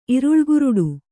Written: ಇರುಳುಕುರುಡು

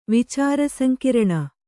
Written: ವಿಚಾರಸಂಕಿರಣ

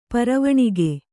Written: ಪರವಣಿಗೆ

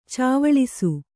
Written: ಚಾವಳಿಸು

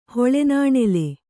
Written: ಹುಳಿನಾಣಲು

